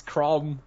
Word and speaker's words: Chrom: The main character's called Crom. How cool of a name is that? – How do you spell Crom with a K? – C-H-R-O-M. – So, Chrome without the E. – It's Crom, yeah.